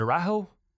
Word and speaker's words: Araujo: Is it Araujo? – It's Araujo. – Araujo. Really? – Yes. Yep.